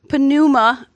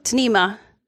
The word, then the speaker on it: pneuma: But in the New Testament, you'll see a word, it looks like pneuma, right? But it's really pronounced more like tnema. That's how you say it. It's like a quick T sort of a sound at the beginning.